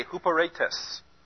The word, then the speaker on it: huperetes: H-U-P-E-R-E-T-E-S. And, back up. Get my pen out. Huperetes would be the better pronunciation for that. Huperetes. These are your long E's here and here, and that's your short E that's got the accent above it.